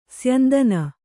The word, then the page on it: ಸ್ಯಂದನ: syandana